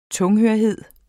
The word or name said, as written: tunghørhed